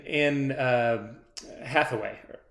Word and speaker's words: Anne Hathaway: If you're going to bring, you know, McConaughey and like, Anne Hathaway. Am I saying that right? Right.